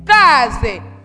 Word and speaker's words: case: La lettre C. Précédé par la voyelle A se prononce K. C se prononce comment? K. Très bien. Ça devient K. Case. Nous avons ici la lettre S. Est-ce que la lettre S est doublée? Non. La lettre S n'est pas doublée. Et elle se trouve entre deux voyelles. Là-bas, elle ne se prononce pas comme S, mais elle se prononce Z.